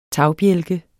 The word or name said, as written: tagbjælke